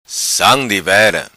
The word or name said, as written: Sam Divera